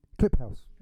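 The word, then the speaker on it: Kliphuis: I spell his name, it's Cliphuis, so I'm not sure how you pronounce it, but Cliphuis. – Because my dad's a big fan. It's Cliphouse.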